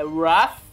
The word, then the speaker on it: Wrath: Exatamente, vamos falar de Infiltrado, ou do título original Wrath of a Man. Filme que estreou aí nos cinemas brasileiros. – Não é of a Man, é Wrath. – Wrath. – É Wrath of Man.